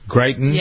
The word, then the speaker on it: Greitens: Missouri Governor Eric, is it Greitens? Is that how you pronounce his name? – That sounds close. – Greitens? – Yeah.